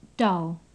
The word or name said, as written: dull